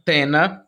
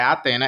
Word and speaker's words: Athena: Athena. Tena, tá, gente? Ela ensina como é que fala o nome dela. Não é Athena, é Tena.